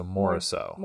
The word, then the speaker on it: Moriceau: Well, it's Frenchish. It's Moriceau. – I always did a soft C at the end, so Morisseau.